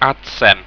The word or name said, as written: adsem